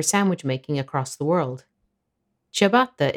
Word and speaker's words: ciabatta: In fact, the only relevant difference is that English speakers don't pronounce the double T. Ciabatta. Ciabatta.